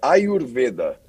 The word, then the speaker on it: Ayurveda: É, a pronúncia correta é Ayurveda.